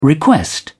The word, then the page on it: request: British English